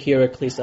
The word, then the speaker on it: Heracles: I tend to pronounce it Heracles as in Greek mythology tradition. The name actually means the glory of Hera. And Hercules, I believe, yes, is the anglicized version of the Latin name.